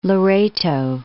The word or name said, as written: Loreto